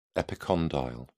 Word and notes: epicondyle: British English

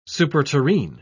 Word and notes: superterrene: British English

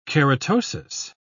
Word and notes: keratosis: British English

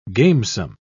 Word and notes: gamesome: British English

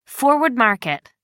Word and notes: forward market: American English

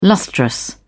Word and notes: lustrous: British English

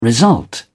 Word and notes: result: British English

